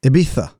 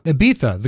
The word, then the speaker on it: Ibiza: Ibiza. – After they were caught. What is it? – Ibiza. – Ibiza. The Castilian pronunciation. – Yes. – Oh, sorry. – Castellano.